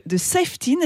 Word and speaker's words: Safetyn: Alors vous êtes cofondateur de Safetyn, est-ce que je le prononce bien? – Oui, très bien. Petit accent anglais, parfait.